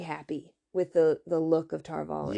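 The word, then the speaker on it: Tar Valon: Yeah, Tar-Valon is how they say it. – In the audio books, they don't say it that way. They say Tar-Valon.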